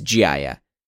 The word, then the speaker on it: Jaya: A brand that stuck out to me that's been a bit of a hybrid of this example, though, is Jaya. First off, yes, that is how you pronounce it, but it's this sort of California cool casual, but all made with extremely luxurious Italian fabrics and construction.